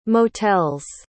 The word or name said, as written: motels